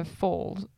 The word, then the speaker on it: foals: What color are the foals? How do you say it? – Foals. – Foals. – Foals. – Foals. – Mm-hmm. Yeah. – Not fools. – Not fools. Foals.